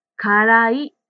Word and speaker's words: 辛い: 9 の辛いという言葉ですが、ジムさんの発音は嫌いと似ています。辛い嫌いの意味が全然違うので、かをはっきり発音するようにしましょう。辛いが正しい発音です。